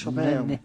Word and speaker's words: Chopin: Claro, es Chopin.